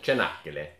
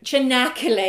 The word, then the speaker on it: Çanakkale: No, it's the northwestern area near Çanakkale. Am I saying that right? – Çanakkale. – Çanakkale, yeah.